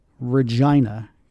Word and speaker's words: Regina: And yes, that's how you say it. Regina. Go ahead and get your giggles out.